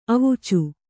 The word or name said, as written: ಅವೀಚಿ